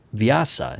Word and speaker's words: Vyasa: named Vyasa. Is that how you pronounce that? – Yes.